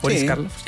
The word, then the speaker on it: Boris Karloff: Cuando uno escucha a Boris Karloff y cuando uno escucha Chistes de... Está bien pronunciado, ¿cierto? – Sí. – Boris Karloff, ya.